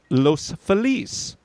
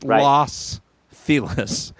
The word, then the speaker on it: Los Feliz: Nor is my neighborhood pronounced Los Feliz. It's Los Feliz.